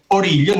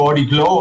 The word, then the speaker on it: Origlio: È un quartetto guidato da Eric Seva, sassofonista, e Alfio Origlio. Noi lo pronunciamo l'italiana perché è originario italiano, lui non parla più molto italiano, probabilmente i francesi lo pronunciano in modo molto strano, tipo Origlo, una roba del genere.